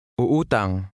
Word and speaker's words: Uutang: Uutang. Uutang. – In Tagalog, when a word contains adjacent vowels, each vowel is pronounced separately with a slight pause between them.